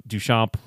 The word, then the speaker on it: Duchamp: Great pronunciation, by the way. – Thank you. – I thought you were going to say Duchamp or Duchamp. – Duchamp. – Duchamp, yeah.